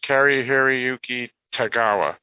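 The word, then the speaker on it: Cary-Hiroyuki Tagawa: His name is Kari Hiroyuki Tagawa.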